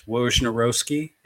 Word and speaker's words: Wojnarowski: That's Wojnarowski.